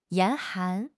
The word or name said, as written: 严寒